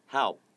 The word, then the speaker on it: help: 근데 잠깐 help 하고 P 발음 안 나잖아요. – 네. – 근데 help 하고 약간 P를 탁 소리를 내주잖아요.